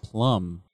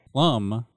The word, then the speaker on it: PLM: The native token used on the Plasm Network is PLM and pronounced Plum. – Oh, okay. – Plum will only calculate from the 15th decimal place and truncate any numbers below that.